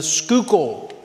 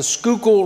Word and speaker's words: Schuylkill: This is a city in the Northeast, and it's on a peninsula between the Delaware River and the Schuylkill if I'm pronouncing that right, the Schuylkill River, between the Delaware River and the Schuylkill River.